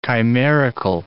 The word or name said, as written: chimerical